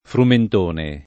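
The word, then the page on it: frumentone: italiano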